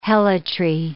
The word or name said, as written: helotry